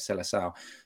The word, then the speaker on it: Seleção: Brazil sort of situation, because when you're a Brazilian and you get chosen for the Seleção, I think they call it, that's how they say it, Seleção, then you have that's a big thing for those guys.